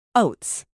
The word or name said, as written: oats